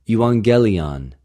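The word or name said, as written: εὐαγγελιον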